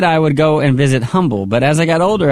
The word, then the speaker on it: Humble: There's no H in humble, right? – Well, there is an H, but it is silent.